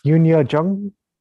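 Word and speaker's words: Youn Yuh-jung: Oh, Yunya Jung.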